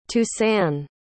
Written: toussaint

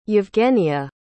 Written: evgenia

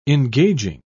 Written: engaging

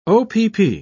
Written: opp

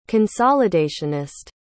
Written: consolidationist

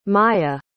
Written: myah

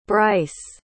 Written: brice

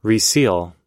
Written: reseal

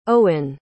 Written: owen

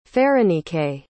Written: pherenike

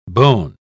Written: bone

